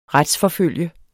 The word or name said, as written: RETSFORFULGTE